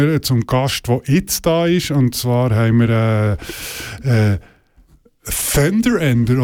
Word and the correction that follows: Tender: oder wie du es genau aussprechen? – Tender Ender. – Tender, ganz normal. – Kommen nicht mit Fender, das ist ganz schlecht.